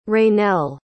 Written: Raynell

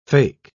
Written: fake

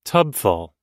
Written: tubful

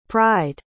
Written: pried

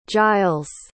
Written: giles

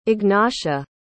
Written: Ignatia

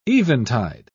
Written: eventide

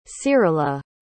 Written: cyrila

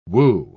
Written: woo

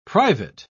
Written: private

